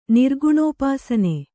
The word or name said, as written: ನಿರ್ಗುಣೋಪಾಸನೆ